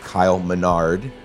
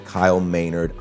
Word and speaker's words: Maynard: In the podcast, I said Kyle Maynard, and it's clearly Kyle Maynard.